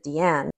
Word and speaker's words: Diane: I like Diane Arbus. We were just talking about I love her. I think she actually pronounced it Deanne.